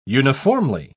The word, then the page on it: uniformly: British English